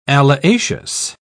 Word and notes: alliaceous: British English

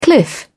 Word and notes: cliff: British English

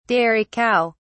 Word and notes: dairy cow: British English

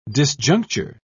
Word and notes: disjuncture: British English

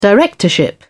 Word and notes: directorship: British English

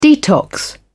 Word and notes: detox: British English

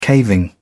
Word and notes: caving: British English

